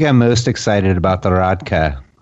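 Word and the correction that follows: rakija: at Belgium. – Rakia, yes. – Rakia, yes. – I always say it wrong.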